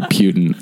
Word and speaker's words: Putin: Vladimir Putin. That's exactly the way his name is pronounced. – Putin. That would be the proper way to announce it.